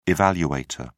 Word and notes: evaluator: British English

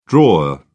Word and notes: drawer: British English

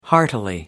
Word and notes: heartily: American English

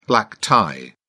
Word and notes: black tie: British English

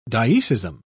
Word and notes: dioecism: British English

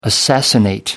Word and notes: assassinate: American English

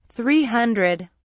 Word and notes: 300: British English

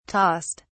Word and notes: tossed: British English

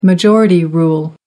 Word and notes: majority rule: American English

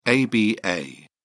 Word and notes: aba: British English

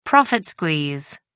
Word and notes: profit squeeze: American English

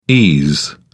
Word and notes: ese: British English

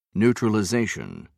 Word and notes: neutralization: British English

